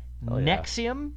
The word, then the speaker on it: NXIVM: But she was involved in a sex cult pronounced Nexium, but it was NXIVM.